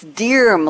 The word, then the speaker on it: Diarmuid: It's actually spelled D-I-A-R-M-U-I-D, but it's Dearm, like deer with an M on the end.